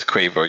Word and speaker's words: Quavo: You know what you're thinking about it, like quake, quaint, quiet. So it is Quavo, you're right.